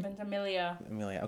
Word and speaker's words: Ventimiglia: I think they had cast, what's his name, Milo Ventimiglia. How do you pronounce his last name? – Ventimiglia. – Okay, so the G is Italian. – The G is silent.